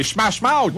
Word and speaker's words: Smash Mouth: C'est Smash Mouth.